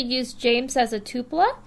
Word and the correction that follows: Tulpa: So, which means... Tulpa. – T-U-L-P-A. Tulpa. – I got it spelled Tulpa. Right, just not pronounced right.